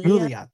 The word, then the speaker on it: Yulia: I think that's how you say her name. – Yulia, thank you. – Yeah, I think so. – It's Yulia.